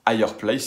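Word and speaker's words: F'Higher Place: Ça va s'écrire F apostrophe Higher Place. On va le prononcer Fireplace, sinon on dirait juste Fireplace et les gens ne comprendraient pas.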